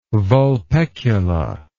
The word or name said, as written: vulpecula